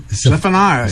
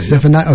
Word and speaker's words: Zephaniah: Zephaniah. – Zephaniah, okay.